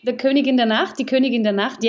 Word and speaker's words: Die Königin der Nacht: Well, first of all, I have to congratulate you for your pronunciation. It's Die Königin der Nacht, yes.